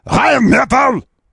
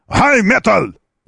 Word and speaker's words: Rheinmetall: Ее надо так произносить из горла, как бы. Rheinmetall! Rheinmetall! Вот так она называется.